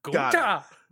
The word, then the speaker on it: Gunther: You always gotta go up on that second syllable. Gunther.